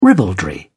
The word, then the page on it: ribaldry: British English